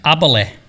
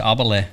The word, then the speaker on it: Aberle: How do I say it? You can now correct me. – Aberle. – Aberle. – Ganz Schwäbisch, Aberle. – It sounds Irish. – No, wie Schwäbisch. Wie hast du gesagt?